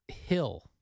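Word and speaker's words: Gil: Hill. – Hill. – They were pronouncing the G with an H. – Phil. – No, Hill. – Bill. – Luis Hill.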